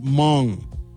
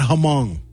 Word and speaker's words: Hmong: And it's pronounced Hmong, not Hmong. Actually, it's Hmong. Hmong, you gotta say it right.